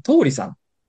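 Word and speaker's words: 桃里: ホテルオークラじゃないんですけど、新宿の中華料理の通りさん。 – 通りさん。 – 桃の里って書いて通りさんって読むんですけど。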